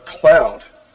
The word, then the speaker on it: Qloud: And finally, I wanted to talk about Cloud, which is spelled Q-L-O-U-D.